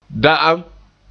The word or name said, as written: da'am